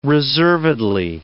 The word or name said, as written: reservedly